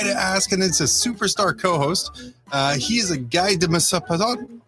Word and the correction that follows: Guy de Maupassant: I mispronounced Guy de Maupassant.